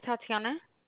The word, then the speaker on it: Tatiana: My name is Tatiana. – Can you spell that? – T-A-T-I-A-N-A.